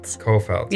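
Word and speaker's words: Cofelt: Afton, I'm going to butcher your last name. – Kofelt. Yeah, okay. – With a C. – C-O-F-E-L-T. E-L-T.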